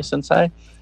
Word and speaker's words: sensei: And then the other, the Cobra Kai sensei, is that how you say it? Sensei? – Sensei.